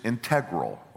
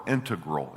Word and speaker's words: integral: The first I is integral or integral. It can be pronounced both ways, and I probably go back and forth.